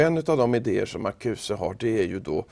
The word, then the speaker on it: Marcuse: Och då står det också i den här artikeln av Oskinis om den tysk-amerikanske filosofen Herbert Marcuse, ska vi väl uttala det då lämpligtvis.